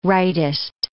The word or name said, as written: rightist